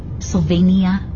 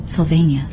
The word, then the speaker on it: Sylvania: Sylvania? I think it's Sylvania. – It's 4 syllables.